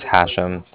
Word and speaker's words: Hashem: So my name is Hashem. That's H-A-S-H-E-M, as in mother at the end.